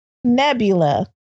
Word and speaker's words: Nebula: I think it's Debula. – Nebula. – It's Nebula. My bad. All right. Thanks for correcting us. Now y'all ain't got to hit me up. The Nebula was down there.